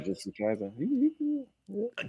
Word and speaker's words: Jujutsu Kaisen: I had a lot of friends this past weekend going to see Jujutsu Kaisen. Did I say it right? – Jujutsu Kaisen.